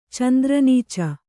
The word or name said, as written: ಚಂದ್ರನೀಚ